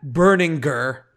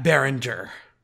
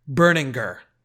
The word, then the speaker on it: Berninger: Yes, it's Matt Berninger, not Matt Berninger. – Berninger? – Berninger.